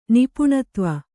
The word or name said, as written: ನಿಪುಣತ್ವ